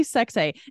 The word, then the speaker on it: Sexay: It's literally Sexay. And I say it weirdly because it's pronounced, or it's pronounced, it's spelled S-E-K-S-E, and there's a hyphen over the last E.